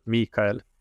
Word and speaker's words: Mikael: And in my native Swedish, it's the emphasis is on the E sound, so Mikael.